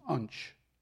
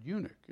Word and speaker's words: eunuch: He didn't know how to pronounce the word eunuch, and if you look at the word, it's hard to pronounce. And so he kept calling him, referring to him during the course of his message as the Ethiopian unch.